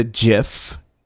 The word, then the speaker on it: GIF: The creator of the GIF says it's pronounced GIF. Now, a lot of people pronounce it GIF, but the creator said it's pronounced, in 2013, he said that it's pronounced GIF, and this is what he said.